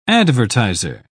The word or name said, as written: advertiser